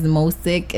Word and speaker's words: Mosich: All right, my boyfriend, his music, his name is Mosic. It's spelled Mosich.